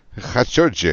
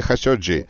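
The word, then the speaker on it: Хашоджи (خاشقجي): Ну что ж, тогда быстро расскажем слушателям, что тема журналиста Хашоги. Он зашел Хашоджи. Хашоджи. Окей, мы используем Хашоджи. Произношение Хашоджи.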